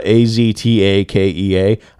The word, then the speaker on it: Aztakea: It's uh, A Z T A K E A. I'm pretty sure it's as uh, Aztakea or whatever,